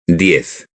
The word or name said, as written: diez